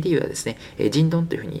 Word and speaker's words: 京: 京都の京に東京の東というふうに書きまして、東京とですね、漢字が全く逆ですね、人ンというふうに読みます。京都の京という字は人というふうに発音しまして、東という字はドンと発音します。